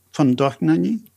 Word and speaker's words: von Dohnányi: Von Dohnányi, am I saying that right? – Christoph von Dohnányi? – Von Dohnányi, yes.